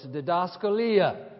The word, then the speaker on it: didaskalia: Well, the word translated teaching in verse 16 here, you've heard some of us refer to it before, is didaskalia. Didaskalia, phonetically it'd be spelled D-I-D-A-S, K-A-L-I-A. D-I-D-A-S-K-A-L-I-A.